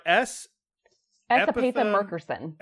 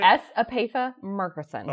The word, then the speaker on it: S. Epatha Merkerson: Played by S. – Epatha Merkerson. – S. Epatha. Okay, how do you say S. Epatha Merkerson.